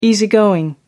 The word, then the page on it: easy going: American English